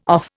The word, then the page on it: off: Indian